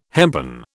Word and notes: hempen: British English

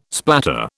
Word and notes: splatter: British English

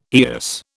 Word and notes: eyas: British English

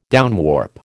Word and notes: downwarp: American English

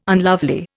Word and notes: unlovely: Indian

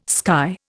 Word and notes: sky: Indian

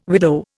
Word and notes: widow: Indian

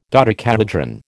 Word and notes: dodecahedron: American English

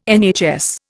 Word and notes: NHS: Indian

